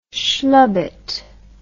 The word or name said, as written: schlubette